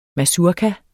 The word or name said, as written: mazurka